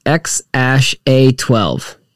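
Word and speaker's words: X Æ A-12: The A is pronounced Ash, but it's X Ash A12.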